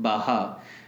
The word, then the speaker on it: Baja: Some people might have heard about it, Baha. Baha is, it's pronounced, spelled as B-A-J-A.